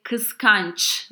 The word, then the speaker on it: kıskanç: But if a person is jealous, then you say kıskanç with the ç sound at the end.